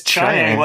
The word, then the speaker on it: Chiang: It's Chang. Well, that's apparently, I'm just going honestly by how they pronounce it in the actual story. – Sure. – So I got to take that as the authority, because Weng-Chiang, I mean, he's supposed to be some Chinese god, I guess, but he didn't really exist.